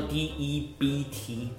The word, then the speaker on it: debt: 那 debt 叫做 D E B T，那只不过很特别是它的 B 不发音，就是所谓的 debt。